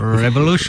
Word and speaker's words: Rebellion: Mais en vrai, ça se prononce comment? – Rebellion. – Ah, merci. – Voilà, c'est plus exactement dans, comme ça.